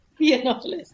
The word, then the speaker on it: Yiannopoulos: Yiannopoulos.